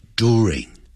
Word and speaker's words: during: One morning，就有那么一天早上呢，during 不要念成 during，啊，就是因为那个 j，就像那个 j 的那个符号啊。那的后头有一个 J 的那个符号呢，那个 J 的音呢，它是不要的，在美式英语它是没有的音，它是直接念 do，在英式英语它念成 during，在美式音呢 during， during her walk to the river，在什么当中叫 during，在她走路到河边当中，she met a man，她就见到阿雄了，这个男子。